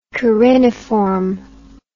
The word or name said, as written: cariniform